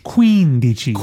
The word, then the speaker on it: quindici: So are you saying quindici because the emphasis is on the first part of that? Okay. – Yeah, I'm pronouncing it like it should be.